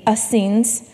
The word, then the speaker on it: Essenes: You have the Pharisees, the Sadducees, according to Google, you pronounce it the Essenes, the Zealots, and the Herodians.